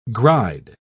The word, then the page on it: gride: British English